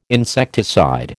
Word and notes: insecticide: American English

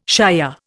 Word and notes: shire: Indian

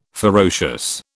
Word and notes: ferocious: British English

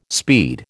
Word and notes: speed: American English

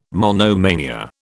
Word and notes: monomania: British English

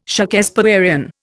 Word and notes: Shakespearian: Indian

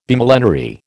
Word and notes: Bimillenary: American English

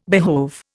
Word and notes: behove: Indian

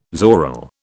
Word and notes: zoril: British English

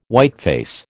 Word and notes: whiteface: American English

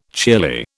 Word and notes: cheerly: British English

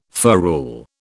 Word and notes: ferrule: British English

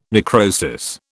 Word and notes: necrosis: British English

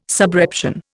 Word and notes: subreption: Indian

